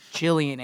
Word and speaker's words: Gillian: And then she was on Hannibal, and I heard it be pronounced Gillian Anderson. I was like, oh, I feel like a fucking idiot.